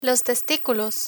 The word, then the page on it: testiculos: spanish